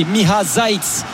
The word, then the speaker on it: Miha Zajc: Et petite réponse, c'est Miha Zajc pour aider Xavier Grimaud dans la prononciation du Slovène de Toulouse désormais.